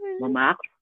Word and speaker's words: Montmartre: I mean, I guess the correct kind of like Frenchified pronunciation would be Montmartre. Like there's like a little huh.